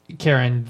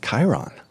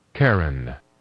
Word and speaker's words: Charon: Is that how you pronounce that? – Charon, the moon, yes. – It looks like Charon. – I always said Chiron. – Let's ask the computers. – Charon.